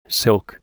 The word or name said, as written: silk